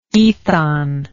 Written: aethon

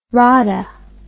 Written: rada

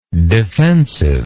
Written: defensiveness

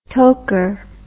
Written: toker

